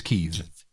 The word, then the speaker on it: Kyiv: The official pronunciation of Kiev is Kyiv.